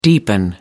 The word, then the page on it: deepening: American English